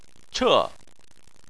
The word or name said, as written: che4